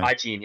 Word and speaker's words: Ajin: And Polygon Pictures, which also did the Knights of Cydonia animation, they did Ajin. I don't know if I said that right. I probably butchered it. But they also did Blame. Yeah. – You're fine. – Ajin, yeah.